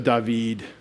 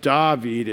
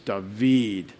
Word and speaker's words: David: You put the accent on the last syllable in Hebrew, generally, not the first. It's not David, it's David. So we say David, but they would say David.